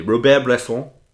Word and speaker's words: Bresson: Robert Bresson.